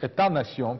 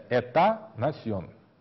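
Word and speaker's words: état-nation: Есть особый тип государства, который называется государство-нация, мы говорили, вот этот «этанасьон» по-французски, «т» последнее не читается, на экзаменах многие говорят «этанасьон». Это надо произносить так, внимательно, «этанасьон», последнее «т» не читается, «эта», без «т», «насьон», государство-нации, или «modern state», современное государство.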